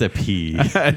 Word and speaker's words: épée: It's épée. – There's an accent on the second E.